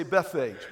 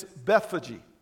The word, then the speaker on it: Bethphage: In English, we always like to say Bethphage, but that's not how you pronounce it. Remember, it was written in Greek. It's Bethphage. That's how you actually say Bethphage.